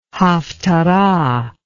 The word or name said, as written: haphtarah